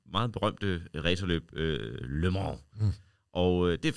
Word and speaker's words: Le Mans: Le Mans.